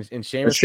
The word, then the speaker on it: Seamus: Yeah, Seamus Casey and Seamus. – I can't even say it properly. – Yeah. Like, I was going to say, it's definitely Seamus.